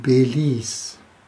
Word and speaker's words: Belize: Einfach Belize geschrieben ausgesprochen Belize, also Police, Polizei.